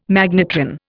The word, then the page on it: magnetron: Indian